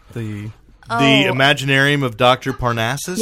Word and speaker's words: The Imaginarium of Dr. Parnassus: The Imaginarium of Dr. Parnassus? – Yes.